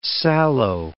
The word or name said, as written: sallow